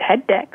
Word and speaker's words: HETDEX: So, yes, you pronounce it perfectly. It's indeed called HETDEX.